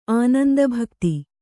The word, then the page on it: ಆನಂದಭಕ್ತಿ: kannada